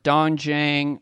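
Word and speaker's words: doenjang (된장): Mine are split red lentil soup or doenjang with tofu and seaweed. I definitely pronounced that wrong. – You nailed it. Nailed it. You had it.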